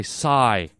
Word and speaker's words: Psy: And Veronica, my strict grammar teacher who wears the high heels and the fishnet stockings, she wrote in and said it's pronounced Psy, S-I. I had it as P-S-Y, Psy, silent P, silent S, silent Y, if that's possible, Psy. Is pronounced S-I, sigh.